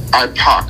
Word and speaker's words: IPAC: IPAC. IPAC like Tupac, IPOC.